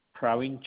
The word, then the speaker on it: Praveen: Praveen Chandra Gajera.